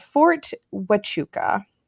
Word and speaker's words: Fort Huachuca: called Fort Huachuca. – Yeah. – Did I say it right? – You did.